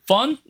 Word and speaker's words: firn: 펀? – 네. – Have fun? 아, 스펠링이 다르네요. – 네. – F-I-R-N.